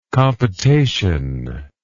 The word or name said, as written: compotation